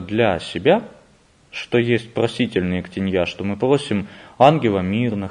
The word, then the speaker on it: ектенья: В слове «ектенья» ударение на последний слог падает. – Да, в служебнике оно стоит прямо очень так уверенно. Более того, там стоит тяжелое ударение, которое означает вот так и никак иначе.